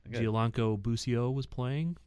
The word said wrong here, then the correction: Gianluca: Gianluca, that's a fine. Dollar in the jar. Gianluca Busio.